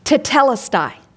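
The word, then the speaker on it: tetelestai (τετέλεσται): The word, and I've heard this pronounced different ways, but I'm going to pronounce it the way I've heard it most often, and that is tetelestai.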